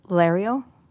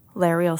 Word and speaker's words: Laeral: Lariel? Is that her name? Lariel Silverhands? – Yeah, I think Lariel is how you say it.